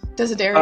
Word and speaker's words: Desiderio: Also, I, I, I want to say David uh, Desiderio. How do you say? That's how you say it. – Yeah, I think so. Desiderio.